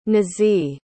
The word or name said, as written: nazih